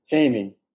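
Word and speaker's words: Cheyney: It's Cheyne University? – Caney. – Okay, Caney University of Pennsylvania, with a degree in mathematics summa cum laude.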